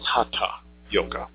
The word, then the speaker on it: Hatha: It's not Hatha yoga, it's Hatha yoga. There is no tha sound in Sanskrit. And if it's properly written, it would be H-A underdotted T, H-A, which would give you the reference to the correct Sanskrit letter and pronunciation.